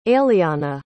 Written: aileana

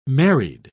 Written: married

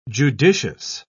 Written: judicious

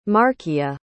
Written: markia

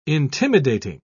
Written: intimidating

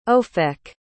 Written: ofek

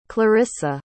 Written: clarisa

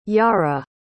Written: yara

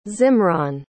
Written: zimraan

